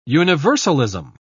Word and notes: universalism: British English

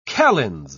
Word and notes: calends: British English

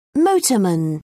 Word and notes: motorman: British English

